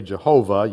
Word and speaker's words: Jehovah: since the Hebrews are not fully persuaded as to how they pronounce that word because of what they have done in the past. We've kind of Latinized it to say Jehovah. Yahweh may be more of the Hebraic way of saying it.